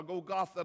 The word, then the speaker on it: Golgotha: And he made his way to that hill called Golgotha, or Golgotha, the way they pronounce it.